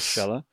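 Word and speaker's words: Schaller: Schuller.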